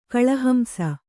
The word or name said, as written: ಕಳಹಂಸ